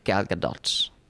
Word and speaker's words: Gadot: Is that how you pronounce Gal Gadot. – It? Gal Gadot. – The T is, yeah. – It's not silent.